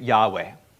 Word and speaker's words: Yahweh: Usually in these days, we pronounce it Yahweh.